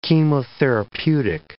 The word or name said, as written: chemotherapeutic